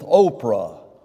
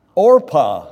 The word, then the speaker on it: Orpah: It rhymes with Oprah. Orpah, all right, Orpah.